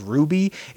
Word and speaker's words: RWBY: Like I said, I wasn't huge into it, but I know like RWBY, which I think is pronounced Ruby, is an anime, an American anime.